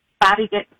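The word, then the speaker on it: Bobbi: Bobby.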